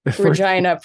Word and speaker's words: Regina: Regina. Pronounce it as it need be pronounced. It's Regina.